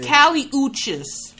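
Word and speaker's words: Kali Uchis: Oh, it's Kelly Uchis. – Uchis. – Yeah. If y'all want to listen to some bomb ass song, listen to Kelly Uchis.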